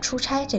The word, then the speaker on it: 出差: 另外，“ 差” 这个字的另外一个读音“ 差”，我们再说一下，“ 差” 就是指被派到别的地方做事情，所以就有了出差这个词。